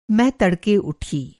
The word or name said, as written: तड़का